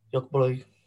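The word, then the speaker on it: Göteborg: Next off is Gothenburg, you want to say it properly? – Jotterboy. – Close enough.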